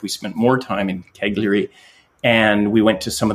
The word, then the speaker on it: Cagliari: I joke that it sounds to my Canadian ears like Calgary, but of course it's not. It's Cagliari.